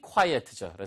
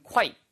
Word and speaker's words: quiet: 이거를 quiet는 quiet이에요. quiet. 이거는 조용한이란 뜻이고요. quiet로 쓰면은요. 그건 quiet. 꽤란 뜻입니다. 그러니까 발음 구분하세요. 얘는 quiet, 그 다음에 꽤라고 할 때는 quite, quite good 이렇게 얘기할 때 쓰는 표현이죠.